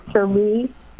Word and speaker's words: Shri: Sherry Tanadar, S-H-R-I. He's a congressman from Connecticut. – Tanadar. – Sheree.